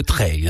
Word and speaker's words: trail: Voilà, et ça se dit le trail, hein. Attention à la prononciation.